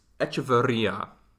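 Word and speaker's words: Echeverría: So the president of this time was Luis Echeverria. So I might have pronounced that a little. – No, you got the R. – Little like, R. It's double R. I know how to roll.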